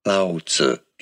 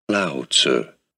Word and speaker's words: Lao Tzu: Chinese pronunciation. Lao Tzu. English pronunciation, Lao Tzu. – It's the same. And yeah, the Chinese pronunciation and the English one are the exact same.